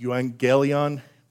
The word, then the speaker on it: euangelion: That word is pronounced euangelion, roughly.